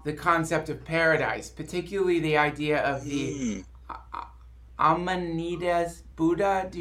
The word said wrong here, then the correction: Amitabha: you know, how do you pronounce it? – Yeah, Amitabha.